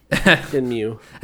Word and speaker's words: Shenmue: Shenmue. – You said it right this time. – I say it right anytime I say it, Evan, so don't worry about it. – It's Shenmue.